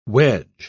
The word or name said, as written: wedge